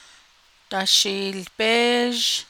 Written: dashiilbéézh

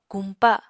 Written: kumpa

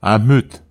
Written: ahmut

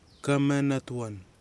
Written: kamenatwan

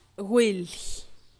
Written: gwilly

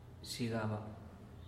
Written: xhigaba

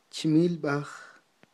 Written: chmilb'aj

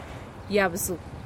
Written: yabdzi

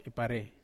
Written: pare

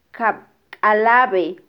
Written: kabkalabe

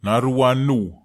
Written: naruan nuw

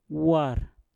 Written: war

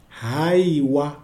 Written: haiwa

